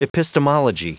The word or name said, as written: epistemology